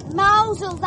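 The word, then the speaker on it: Mosul: Mousel, that's how it's pronounced. – What are you talking about? – It's spelt mouse hole, but they call it Mousel.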